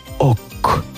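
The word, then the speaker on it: oc: Wykonywali oni swoje utwory w języku oksytańskim, działali w krainie południowego dialektu języka franków, takiego, w którym słowo francuskie tak, czyli oui, wymawiano jako ok.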